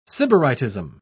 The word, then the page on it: sybaritism: British English